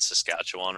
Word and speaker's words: Saskatchewan: Can we also, for all the Canadian listeners, take a moment to appreciate that Bender said Saskatchewan, right? I don't know if I've. How many Americans can say that they can read Saskatchewan and pronounce it correctly?